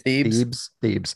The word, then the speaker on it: Thebes: Thebes.